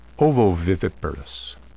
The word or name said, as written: ovoviviparous